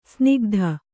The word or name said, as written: ಸಿನಿಕತೆ